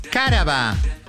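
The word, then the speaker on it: cáraba: Vamos a decir la primera palabra de esta mañana es caraba. – ¿Eh? ¿Cómo? – Caraba, no. Es trújula, es con acento en la A.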